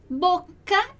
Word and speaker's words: bocca: Bocca in italiano, due C, então atenção à pronúncia, bocca, bocca, due C, ok?